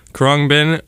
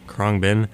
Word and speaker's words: Khruangbin: Krongbin, if you're curious and want to look him up, it is spelled K-H-R-U-A-N-G-B-I-N. Krongbin. Metal Airplane.